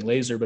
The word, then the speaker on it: Laaser: And, you know, my last name being Laaser, but it's spelled L2A's S-E-R rather than L-A-S-E-R.